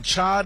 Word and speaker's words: chai: National Chai Day. – Okay. – Did I say that right? – Yes. Like chai lattes.